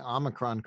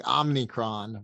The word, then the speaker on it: Omicron: And congratulations to you on pronouncing Omicron correctly, because 90% of people add an extra N and say Omicron, which is not in fact correct.